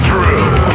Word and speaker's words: Drew: My name is Drew. Pronounced with the hoo.